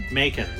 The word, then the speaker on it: Macon: Macon. Rhymes with bacon.